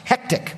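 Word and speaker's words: Heqet: They had an idol named, it's H-E-Q-E-T in English. It's pronounced Hectic, which is kind of funny.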